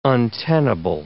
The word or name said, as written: untenable